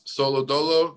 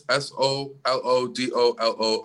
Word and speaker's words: Solodolo: Uh, you can find me on Twitter at Chris Solodolo. That's Chris S O L O D O L O underscore.